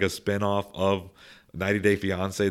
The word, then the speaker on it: Fiancé: Is that how you say it? Is that correct? – Yes, 90 Day Fiancé.